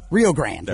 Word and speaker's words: Rio Grande: I always say Rio Grande. – Rio Grande. There we go. The E is silent.